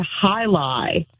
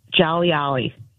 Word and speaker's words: jai alai: And my mom and I were working together on it, and I saw the word hi-li, which is spelled J-A-I-A-L-A-I, and I pronounced it Jolly Ollie.